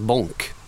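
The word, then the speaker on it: Bąk: Det där, jag hade en spelare 2002 som hette Jerzyk Bak, B-A-K, polsk spelare. Och polacken hörde av sig, han uttalas Bonk, B-O-N-K ungefär. Men det står ju B-A-K, var går gränsen?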